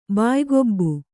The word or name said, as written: ಬಾಯಿಕೊಬ್ಬು